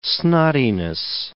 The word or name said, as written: snottiness